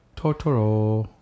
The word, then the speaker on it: Turturro: Turturro.